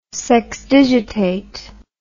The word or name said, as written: sexdigitate